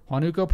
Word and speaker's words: Huánuco: According to the cops, this bloodthirsty band of ghouls were murdering people up and down how would you pronounce that? Huánuco? – Sure. – Huánuco province solely to extract their fat.